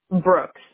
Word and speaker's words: Brooks: Brooks. B-R-O-O-K-S.